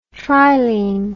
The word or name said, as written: trilene